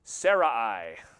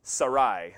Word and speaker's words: Sarai: And he marries this woman that I always grew up hearing her name pronounced Sarai, but probably would have been pronounced Sarai, and that makes a lot more sense to my ears anyway.